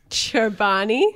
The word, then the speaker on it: Chobani: Cholbani? Is it Cholbani? Is that what it is? – Well, it's Chobani.